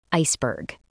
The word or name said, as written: iceberg